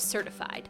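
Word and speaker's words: Certifid: His name is Tyler Adams, and he's the co-founder and CEO of Certified, spelled like Certif-ID.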